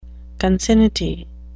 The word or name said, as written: concinnity